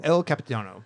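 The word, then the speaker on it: Io Capitano: El Capitano. – El Capitano. There we go.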